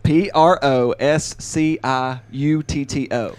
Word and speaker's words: prosciutto: P R O S C I U T T O.